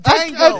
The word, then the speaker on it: Django: It's just Django. – You gotta call him Dango. – I keep forgetting the D is silent. – Django.